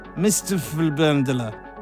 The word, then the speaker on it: MRPHLNDR: Now, the reason that that's pronounced like that is because there's no vowels in this. MRPHLNDR. That pronounced as Mr. Flubendler, which is the alter ego of Alex Morph, who another name in the hard edge trance scene.